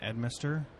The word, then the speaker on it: Edmister: Today, I am honored to be with Brian Edmister.